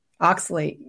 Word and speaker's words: oxalate: So this, did I pronounce it right? Oxalate? – Oxalate. Very good.